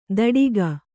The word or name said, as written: ಧಡಿಗ